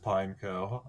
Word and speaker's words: Pineco: It is pronounced Pineco.